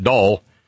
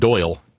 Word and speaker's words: Dahl: Then this maggot named Dahl, that's how we pronounce it down here at least, Dahl, it might be pronounced Doyle elsewhere, but around here it's Dahl, just barely an entire syllable, Dahl, kind of like that stuff you lubricate your car with, all.